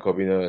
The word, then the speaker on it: Cobbina: So Brendan spelled B R E N D A N, and then Cobbina is spelled C O B B I N A.